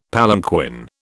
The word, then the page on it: palanquin: British English